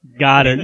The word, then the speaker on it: Goedert: Telling me Goddard, this, that is French. I heard Al Michaels. I've listened to like 3 announcers and broadcasters say Goddard over the past 2 weeks.